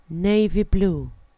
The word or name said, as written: navy blue